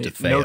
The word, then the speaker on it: DeFeo: Anyway, so what happened here is a family by the last name DeFeo, which I thought it was Defoe at first, and I was like, wow, that's awesome. – I think it's actually pronounced DeFeo.